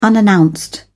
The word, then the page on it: unannounced: British English